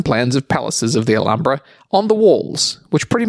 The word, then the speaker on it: Alhambra: It's a Spanish word, so the H is silent. Alhambra.